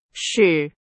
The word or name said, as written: 使